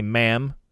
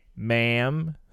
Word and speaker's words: ma'am: On presentation to the queen, the correct formal address is Your Majesty, and subsequently ma'am, pronounced with a short A as in jam. Ma'am. Ma'am. Okay, so you don't want to stress the am part.